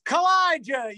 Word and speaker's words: Kaw-Liga: And I go, Colega. And he goes, spell it. And I'm like, K-A-W-L-I-G-A. He goes, that's Kalija, you moron. And I'm like, oh, you know, it's not pronounced how it's spelled.